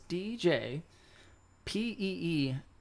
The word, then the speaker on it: Peewhee: That is DJ Peewhee. Yep. There's that silent H in there, you know, yep.